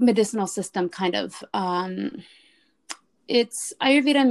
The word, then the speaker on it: Ayurveda: Ayurveda. – Say it again, please. – Ayurveda.